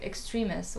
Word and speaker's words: Extremis: How do you pronounce it? – Extremis. Why is it so hard for you? – Because it's like a Latin word. It's like the Latin word, so...